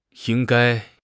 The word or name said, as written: ying1 gai1